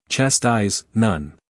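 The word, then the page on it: chastise: uk tʃæˈstaɪz; en-us tʃæˈstaɪz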